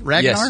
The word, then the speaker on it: Ragnar: Am I saying that right? Ragnar? – Yes.